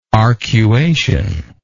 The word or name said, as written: arcuation